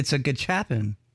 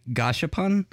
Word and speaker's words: gachapon: It's a gachapon. That's how you say it. – Okay. I didn't know if it was like some weird name. – It's not Oreos, if that's what you're wondering. like it's one of the few that looks like it's spelled. It's the like most Japanese people say gashapon, but you can say it the way it looks.